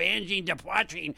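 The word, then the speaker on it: Angine de Poitrine: That was Angene de Poitrine. – Yes, that sounds very good. – There was Pierre, the technical clinician and specialist here at the station, and he said that my pronunciation of Angene de Poitrine was pretty good.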